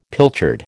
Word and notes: pilchard: American English

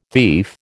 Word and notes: thief: American English

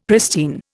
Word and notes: pristine: Indian

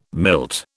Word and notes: milt: British English

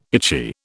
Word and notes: itchy: British English

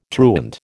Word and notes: truant: American English

